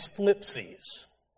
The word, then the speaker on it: thlipsis: The Greek word used here is thlipsis. It's spelled T-H-L-I-P-S-I-S, thlipsis.